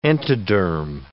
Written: entoderm

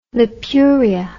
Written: lipuria

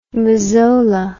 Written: mazola

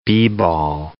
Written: b-ball

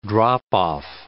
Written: drop-off

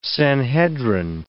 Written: Sanhedrin

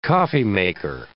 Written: coffeemaker

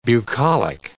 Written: bucolic

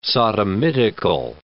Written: sodomitical

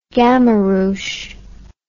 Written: gamaruche

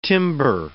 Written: timber

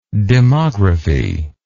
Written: demographist

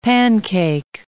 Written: Pan-Cake

